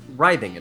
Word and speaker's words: writhing: I also have to be the pronunciation police, because earlier, you said... writhing in pain, when I believe it's pronounced writhing in pain. – I meant to say writhing.